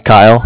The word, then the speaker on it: Kyle: It's Kyle, K-Y-L-E. Calling over here with the Bank of Cardiff, and Cardiff is spelled C-A-R, D as in David, I-F-F, like Frank.